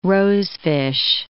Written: rosefish

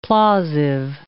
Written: plausive

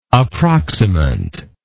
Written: approximant

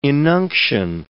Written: inunction

